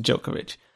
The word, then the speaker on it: Djokovic: Well, Djokovic, I think I've been corrected on Twitter, and I've listened to people talk, and I've listened on Wikipedia. His name is pronounced Djokovic.